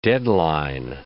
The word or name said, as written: deadline